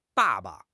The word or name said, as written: ba4 ba5